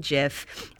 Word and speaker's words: GIF: I have changed how I pronounce GIF. I used to say GIF. I'm now saying GIF for you. – GIF is correct.